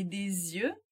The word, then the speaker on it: des yeux: E des yeux, olhos, tem o S e o X finais omitidos na pronúncia.